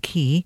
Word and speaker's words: Chy: And that is spelt, better spell it for you, it's pretty unusual, C-H-Y, which I'm pronouncing key, Rasom, R-A-Z-O-M.